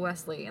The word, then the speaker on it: Wesleigh: Yep, everywhere I'm at Hello Wesley, and that's spelled W-E-S-L-E-I-G-H.